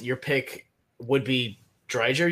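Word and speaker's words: Driedger: So it is Dreiger. Okay. – I guess we're just ignoring the D in the middle of the name. – The silent D.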